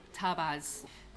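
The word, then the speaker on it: tabac: Okay, so let's talk about tabas. Please notice the pronunciation, it's pronounced ta-ba. The C is silent. – Yeah. – You will never be a Parisian if you pronounce the C.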